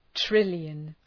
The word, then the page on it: trillion: ‘trıljən